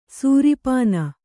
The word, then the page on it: ಸೀರ್ಪನಿ: sīrpani